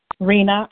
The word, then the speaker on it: Rina: Rina, who's that? – Rina, R-I-N-A, in New Jersey.